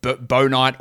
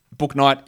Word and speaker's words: Bouknight: Worst name in the draft, because it's definitely not Bouknight. It is absolutely Bo-Knight or Boo-Knight. Bouknight is a horrendous pronunciation, but that's what he calls himself, so let's go with it.